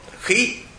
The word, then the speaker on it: khí: Còn hiện tại thì khứ quốc thành, khứ vương vị, khứ thê no. Khứ là bò. Khí, đọc là khí mới được.